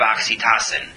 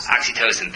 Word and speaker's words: oxytocin: oxytocin. And the result of that, am I pronouncing that right? I hope I got the chemical name right. No, I'm not. Oxytocin, thank you.